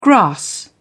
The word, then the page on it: grass: British English